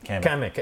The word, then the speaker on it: Kamek: Kamek.